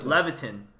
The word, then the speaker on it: Levitin: How do you pronounce his name? The author? Daniel Levitin. – Levitin. Yeah. – That's pretty clear.